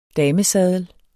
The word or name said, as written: damesaddel